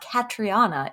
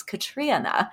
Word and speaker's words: Catriona: It's not Katriana, it's Katriana.